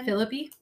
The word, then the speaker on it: Phillippe: How do you say this last name again? – Phillippe? – Yes.